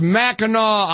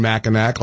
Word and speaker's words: Mackinac: Of course, it's spelled Mackinac, and I was saying Mackinac like a dummy. And my text line has blown up, and people are calling poor Alex. And I know it's Mackinac Island. I apologize. I pronounced it the way it is spelled. That's a strange deviation of the spelling, isn't it? It's Mackinac Island, not Mackinac.